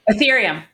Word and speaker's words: Ethereum: He's using mostly Urethrum. Am I saying that right? – Ethereum.